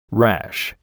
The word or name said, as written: rash